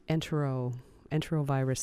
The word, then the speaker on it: Enterovirus: Let's move on to enterovirus 68. Mm-hmm. Am I pronouncing that correctly? Enterovirus 68. – Yes.